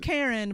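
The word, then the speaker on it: Caryn: Karen, but it's spelled kind of snazzy. Yeah. It's C-A-R-Y-N.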